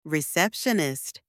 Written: receptionist

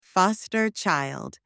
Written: foster child

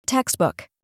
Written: textbook

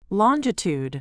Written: longitude

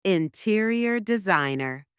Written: interior designer

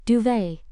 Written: duvet

